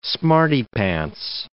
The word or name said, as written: smarty-pants